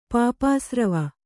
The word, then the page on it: ಪಾಪಾಸ್ರವ: kannada